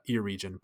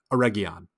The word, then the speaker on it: Eregion: So when I saw Eregion, I just pronounced it like any English reader would, Eregion. But it is, of course, Eregion.